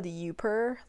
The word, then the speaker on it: Youper: It's called Youper, like U-P-E-R, or it's spelled Y-O-U-P-E-R, and you can set, like, 2 notifications.